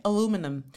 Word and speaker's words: aluminium: So how do Americans say aluminium again? Aluminum. Aluminum.